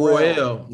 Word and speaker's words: Worrell: Bill Worrell or Bill Worrell? – Worrell, yeah.